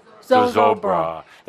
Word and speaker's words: Zozobra: And they do this thing where they, the wonderful thing where they do the burning of Zoroba, is that how you say it? – Zozobra. That's the night before we start.